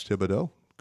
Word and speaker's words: Thibodeau: And I think you're the first people to pronounce Thibodeau the right way in a long time. – well, you're Thibodeau. – I'm married. – E-A-U-X, but you've got a name that you could easily be from Louisiana, and you've done some reporting.